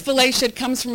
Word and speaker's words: Valatie: V-A-L-A-T-I-E, so V like Valentine, A like apple, L like lemon, A, T like toy, I-E. It sounds like Valaty.